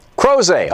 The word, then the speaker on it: Crozet: Crozet, Virginia. Am I saying that right? – Crozet.